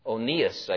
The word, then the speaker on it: Oannes: His name was, I don't know how you say it, O-A-N-N-E-S. Oneus, I think, is the way you pronounce it.